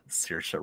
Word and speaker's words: Saoirse: Saoirse Ronan, thank you.